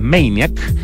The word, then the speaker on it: Maniac: Este libro que se escribe en Maniac, el título, pero no voy a decir por qué, ya lo entenderán cuando lo lean, hay que pronunciarlo en inglés, Maniac.